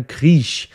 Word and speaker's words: Krieg: Eine weitere Vermutung ist, dass hier nicht das deutsche Wort Grieche von den Einwohnern Griechenlands abstand, sondern das Wort Krieg mit K am Anfang, ja, was eben Krieg bedeutet und im Kölschen Zungenschlag gerne Kriech ausgesprochen wird und damit fast Wort, also soundmäßig dem Griech ähnlich ist.